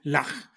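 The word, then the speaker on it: Llach: Pronúnciese, si se pronuncia como Bach, sería Lach.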